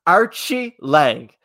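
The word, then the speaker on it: Aori Qileng: So Archie Lang. That's how you say his name. I didn't know that, and Craig taught me. So it's Archie Lang.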